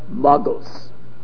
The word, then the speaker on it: Magos: He says, Hareze Magos. That's the way I'm pronouncing it. Why am I pronouncing it this way? – Magos. – Because this became a word used both in Latin and in Greek.